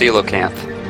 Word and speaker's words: coelacanth: It's actually pronounced coelacanth.